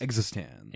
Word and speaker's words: eXistenZ: They say it in the movie. It's like EXISTENZ. It's like EXISTENZ. – EXISTENZ.